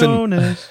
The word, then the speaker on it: Jonas: Okay, sorry, I'm going to say it like an American. – My name Jonas is Jonas.